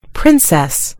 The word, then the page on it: princess: British English